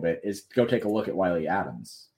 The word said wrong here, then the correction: Adames: because or Adames. I don't remember how to spell it. – Willie Adames.